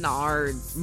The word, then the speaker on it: Menards: I'm gonna go down to the Menards. I don't know how you pronounce that shit. – Menards.